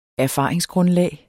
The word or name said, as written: erfaringsgrundlag